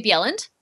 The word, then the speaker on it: Bjelland: I'm also at Tammy Bjelland.